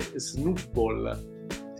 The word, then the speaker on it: Snubbull: Sì, proprio, allora, questo libro qua è proprio eh, scritto come si pronuncia ed è Snoopball, signori miei.